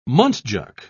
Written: muntjac

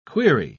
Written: query

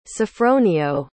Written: sofronio

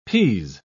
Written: pease

